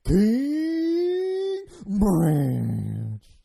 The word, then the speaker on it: King Ranch: You have to elongate everything. So it's King Ranch. So the longer you can stretch that out, the longer you stretch it out, the more proper that is.